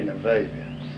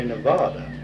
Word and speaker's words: Nevada: Nevada. I tell you, most people say Nevada, but now some of the people that help get the county established say Nevada. Ex-Governor McRae, he always pronounced it Nevada.